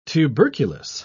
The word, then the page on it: tuberculous: British English